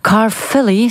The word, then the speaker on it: Caerphilly: Dat zei het pas verkozen lid van het Welsh parlement, Lindsay Whittle, toen onze ploeg hem daar opzocht in het stadje Caerphilly, zo moet ik dat zeggen, in de buurt van Cardiff.